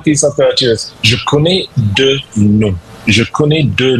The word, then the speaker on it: je connais deux noms: Je connais deux noms.